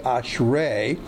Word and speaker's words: ashrei: In the Hebrew, that's the word ashrei, A-S-H-R-E, long E pronounced A, ashrei.